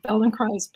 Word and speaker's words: Feldenkrais: In fact, we try to do a service and we say it rhymes with paradise. So Feldenkrais paradise.